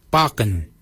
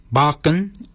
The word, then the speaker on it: baginy: For beard, we say Bakin. Bakin is spelled B-A-G-I-N-Y. Bakin. Bakin is your beard. The upper mouth, we say Warrigal. Warrigal. Warrigal is spelled W-O-R-R-A-G-O-L.